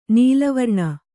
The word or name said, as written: ನೀಲವರ್ಣ